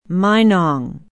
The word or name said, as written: minong